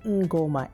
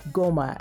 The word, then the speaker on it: Ngoma: Ngoma, N-G-O-M-A. Is that how it's said? Ngoma, or is it just Goma and the N is silent?